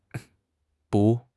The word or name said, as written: dou4